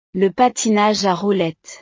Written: le patinage a roulettes

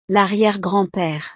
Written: l'arriere-grand-pere

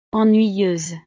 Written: ennuyeuse

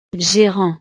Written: gérant

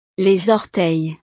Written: les orteils

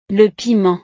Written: le piment

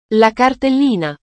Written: la cartellina

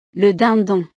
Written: le dindon

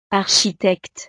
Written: architecte

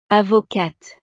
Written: avocate

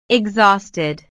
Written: exhausted

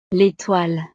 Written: l'étoile

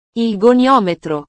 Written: il goniometro

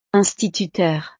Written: instituteur

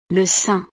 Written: le sein

